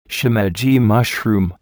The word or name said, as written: shimeji mushroom